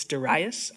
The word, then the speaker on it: Darius: I kept pronouncing it Darius, and then I listened to it like on, and it's Darius. I learned this really quick. So if you didn't know, it's Darius.